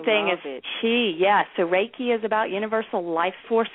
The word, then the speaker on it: Reiki: And in Japanese, it's more like Leiki because the R's become L's. But in English, it's just pronounced Reiki. If you think of, it's spelled R-E-I-K-I, but if you think about a ray of sunshine, then you can get started the right way.